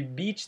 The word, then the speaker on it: beach: A pronúncia de beach, tem muita gente que tem dificuldade, né? Porque parece com aquele palavrão, mas é só falar mais parecido de um B-I que você vai acertar.